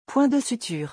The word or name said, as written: points de suture